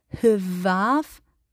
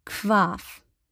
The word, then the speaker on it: hvarf: Und wenn ein H und ein V zusammen ist, dann wird das wie im Deutschen das K ausgesprochen. Also man liest es nicht im Isländischen wie Hvarf, man sagt Kvarf.